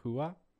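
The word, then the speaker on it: Rua: Shogun, I would have got Hua. – It's Portuguese, so the okay. R is an H. – Yeah, if anything Portuguese, just pronounce the R's as H's.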